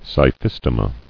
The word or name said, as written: Scyphistomae